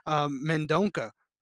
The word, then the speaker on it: Mendonça: Nate Maness versus Mendonça. Shout out to my slight Portuguese that I know. That's the only reason I know how to pronounce that C.